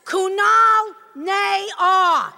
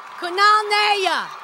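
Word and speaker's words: Kunal Nayyar: Kunal Ney are. Oh, no, no, no. Naya, Naya, Naya. Kunal Naya.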